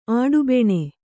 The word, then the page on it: ಆಡುಬೆಣೆ: Kannada